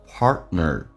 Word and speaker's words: partner: 我们来看一下这个单词叫 partner，那么 t 跟 n 在一起，通常 t 是不发音，在美式发音当中，那么通常读到这边气流稍微的阻塞一下，但不要过于明显，这个阻塞叫做 partner， you got me Partner 跟你的另一半 through escaping into work, hobbies, television, or other activities.